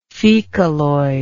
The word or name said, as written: fecaloid